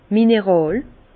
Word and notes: mineràl: Alsatian; Bas-Rhin; Herrlisheim

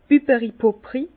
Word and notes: Bübberi-Pupri: Bas Rhin; Herrlisheim

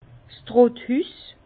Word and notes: 's Trotthüss: Bas-Rhin; Herrlisheim

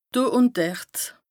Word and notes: do ùn dert: Bas-Rhin; Strasbourg

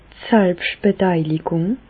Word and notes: d' Selbstbeteiligùng: Bas-Rhin; Reichshoffen

